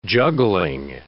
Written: juggling

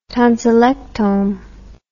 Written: tonsillectome

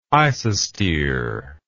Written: isostere

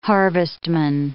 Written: harvestman

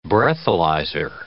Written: Breathalyzer

